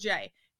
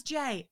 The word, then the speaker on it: Jae: So she just, like, picked out a player that she was watching that night, and it was Jay. And she loves that his name is spelled J-A-E. So she's always like, oh, is that Jay? J-A-E? I'm like, yeah, Mom, that is Jay.